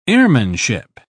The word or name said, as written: airmanship